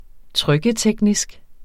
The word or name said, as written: trykketeknisk